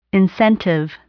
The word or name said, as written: incentive